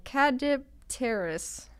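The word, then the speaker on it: Caudipteryx: It's called Cadipteris. – Okay. – Sure, that's pronounced right. No, that sounds exactly right.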